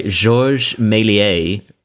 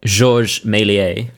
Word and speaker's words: Méliès: a movie by Georges Méliès from 1896. – How do we feel about that pronunciation? – What? I'm very happy with it. – Oh, okay, great.